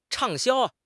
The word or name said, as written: 畅销